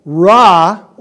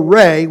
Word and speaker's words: Ra: So Ra or Re, whichever way you want to pronounce it, because it's pronounced as both, R-E or R-R-A, he was worshipped as the father to Pharaoh, as deity.